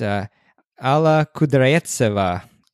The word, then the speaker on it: Alla Kudryavtseva: So the first person that we have is Alla Kudryavtseva. You're welcome for that beautiful pronunciation there, which I practiced for like 30 minutes.